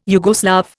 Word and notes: Yugoslav: Indian

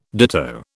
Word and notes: ditto: British English

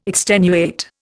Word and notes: extenuate: Indian

